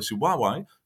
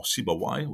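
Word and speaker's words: Sibawayh: The second book by Sibawai, or Sibawai, whatever you want to pronounce his name, is meant actually for learners of the Arabic language that are trained or that have a native language of another language.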